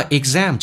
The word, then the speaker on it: exam: 那么 exam 这个单词很难发，首先那个 g 是不用发音的，叫 e 气流阻塞一下变成 exam，而且没发音 a，后面跟的是 m 或者 n 的时候，中间要加一个 a 这个发音，为了更加的顺畅，变成 m exam exam was 跟 exam 有一个连音，叫 was exam was exam。